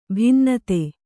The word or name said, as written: ಭಿನ್ನತೆ